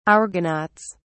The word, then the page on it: argonauts: British English